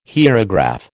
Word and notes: hierograph: American English

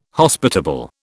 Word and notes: Hospitable: British English

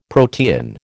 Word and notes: protean: American English

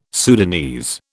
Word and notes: Sudanese: British English

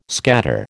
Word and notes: scatter: American English

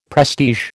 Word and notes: prestige: American English

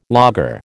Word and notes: lager: American English

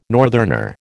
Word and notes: northerner: American English

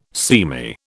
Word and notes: seamy: British English

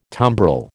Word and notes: tumbrel: American English

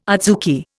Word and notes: adzuki: Indian

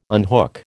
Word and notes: unhook: American English

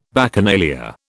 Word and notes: Bacchanalia: British English

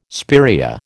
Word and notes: spiraea: American English

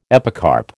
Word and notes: epicarp: American English

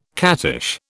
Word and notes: cattish: British English

